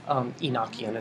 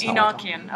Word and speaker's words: Enochian: Enochian is Enochian.